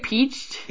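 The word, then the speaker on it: Pietsch: Peach? P-I-E-T-S-C-H. I think it's Carrie Peached. – It's pronounced Peach.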